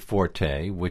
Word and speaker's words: forte: Well, then we wind up with forte, now has become the standard pronunciation. – Exactly. We pronounce it as if it were an Italian word. It's not. It's an American word, fort, or English word, fort.